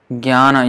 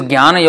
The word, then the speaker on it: Jnana: Many devotees say jnana, jnana. No, it is not jnana. It's jnana, jnana. It is, the tongue should be at j, j, but then you don't pronounce the j, jnana, but jnana, jnana.